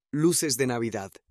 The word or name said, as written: luces de Navidad